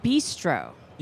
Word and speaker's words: Bistreaux: Louisiana Bistro. – Yeah, that was Yeah. But it's spelled Bistro, B-I-S-T-R-E-A-U-X.